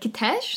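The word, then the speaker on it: Kitezh: And now when we watched this Werner Herzog thing, he said Kitesh. So we're like, oh. – I think Kitesh probably sounds... – We were saying it wrong the whole time.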